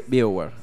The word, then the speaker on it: BioWare: más específicamente por BioWare, o no sé cómo es la pronunciación. – BioWare, está bien. Perfecto.